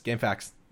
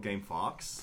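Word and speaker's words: GameFAQs: Game Facts. – Yeah, I always used to call it Game Fox. – Oh, no. – Because Q would make me pronounce the word differently.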